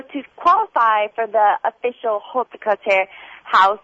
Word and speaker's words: haute couture: And what is, I'll probably pronounce this wrong, and I'll have the French woman here, the haute de couture, I think, and ready to wear. – Haute couture is designer wear.